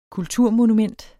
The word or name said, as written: kulturmonument